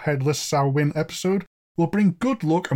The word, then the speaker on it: Samhain: Come Samhain in Scotland, where I believe it's pronounced more Savin, you have offerings left out Halloween time for the country's fairy cats.